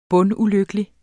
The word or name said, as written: bundulykkelig